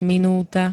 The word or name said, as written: minúta